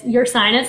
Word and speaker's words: Ursinus: But, yeah, it's Ursinus, so, like, not – Ursinus, my sinus, your sinus.